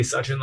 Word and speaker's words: Sachin: In brackets, it said Sachin, pronounced Sachin. – Yeah. – And I say Sachin like such and such because it's true.